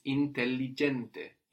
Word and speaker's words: intelligente: No, the word for intelligent is intelligente. Intelligente. We have two L's there and we pronounce them both.